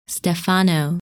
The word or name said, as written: Stephano